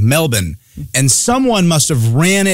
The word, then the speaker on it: Melbourne: I never heard it pronounced correctly. And then all of a sudden, it was just over my head. Melbourne. – Yeah, but that's only for the locals. We got to say Melbourne.